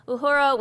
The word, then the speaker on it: Uhura: O'Hara. How do you say it? – Uhura. – Uhura. Ah, I thought I knew it, and then I said it. It sounded wrong.